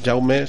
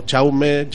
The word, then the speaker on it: Jaume: Y también, eh, diciendo con el tema de las J, las X y las CH, mi mi nombre, Jaume, se escribe con J, aunque también se puede decir como Chaume, Jaume o Jauma, como queráis. Y si eres de aquí de Madrid, Jaime.